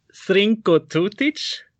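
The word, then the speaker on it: Zrinko Tutić: Your song then, Haide da Ludujemo, was composed by, and please help me here if I say this wrong, the pronunciation, Srinko Tutić. – Yes, and, perfect.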